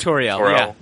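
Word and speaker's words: Tauriel: There was no Evangeline Lilly, Toriel. Is that how you say it? – Toriel.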